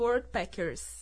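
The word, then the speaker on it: Worldpackers: É World Packers.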